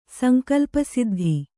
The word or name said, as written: ಸಂಕಲ್ಪಸಿದ್ಧಿ